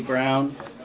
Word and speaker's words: Brown: My name is Kelly Brown. It's spelled K-E-L-L-E-Y, Brown, B-R-O-W-N.